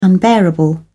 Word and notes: unbearable: British English

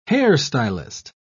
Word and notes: hairstylist: British English

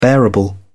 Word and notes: bearable: British English

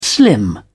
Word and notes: slim down: British English

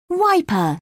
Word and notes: wiper: British English

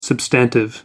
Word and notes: substantive: American English

